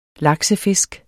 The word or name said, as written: laksefisk